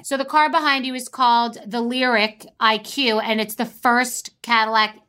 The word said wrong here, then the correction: Lyriq: It's called the Lyriq, and it's spelled L-Y-R-I-Q.